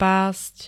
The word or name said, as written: past